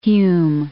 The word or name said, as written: Hume